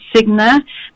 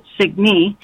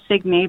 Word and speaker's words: Signe: My name is rooted in Scandinavian language, and it's pronounced Signe, but I grew up in the U.S. from Colorado, and my parents found my name in a book, and they pronounced it Signe. So I identify as Signe,